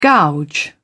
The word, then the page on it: gouge: British English